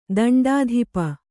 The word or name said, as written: ದಂಡಾಧಿಪ